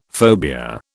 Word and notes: phobia: British English

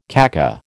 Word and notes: kaka: American English

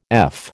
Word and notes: eff: American English

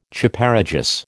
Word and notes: Chaparejos: American English

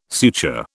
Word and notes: suture: British English

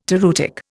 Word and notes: toreutic: Indian

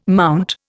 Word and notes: MT: Indian

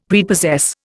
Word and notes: Prepossess: Indian

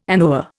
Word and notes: anoa: Indian

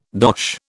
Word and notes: dosh: British English

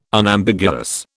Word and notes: Unambiguous: British English

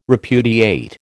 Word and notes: repudiate: American English

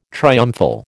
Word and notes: triumphal: American English

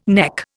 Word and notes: neck: Indian

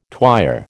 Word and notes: twyer: American English

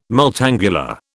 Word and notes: multangular: British English

